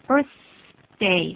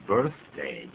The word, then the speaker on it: birthday: 用个 s 来代替，其实这个 th 的音很好发音哦，把舌头吐出来，大概只有半公分就够了啊，这个就好了，birthday 就对了。 – birthday 这样就可以。